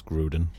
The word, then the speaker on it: Grodin: I always get his surname wrong. – It's Charles Grodin. – Charles Grodin. There we go.